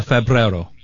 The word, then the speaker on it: febrero: Febrero. – Well, you're close. – You're close. – All right, how is it? – E is A. Febrero. – Febrero. – E is always pronounced A.